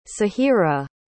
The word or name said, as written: sahirah